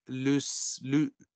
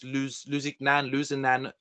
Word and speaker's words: Lusignan: Guy de Lusignan, L-U-S-I-G-N-A-N. It's a French pronunciation.